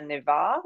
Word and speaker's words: Nevar: So I am on Facebook and Instagram as Anita Nevar, which is last name is spelled N-E-V for Victor A-R.